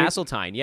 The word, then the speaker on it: Hasseltine: Didn't you meet like Eric Hasseltine? – Yes, am I getting the name right? – Hasseltine, yeah, the Grizzlies radio guy.